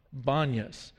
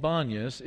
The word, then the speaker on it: Banias: Now, the reason is that it's sometimes called Banias is because the letter P is not natural to the Arabic alphabet and language, and so all of the P's are pronounced as B. So if your granddad is Papa, in Arabic he would be Bapa.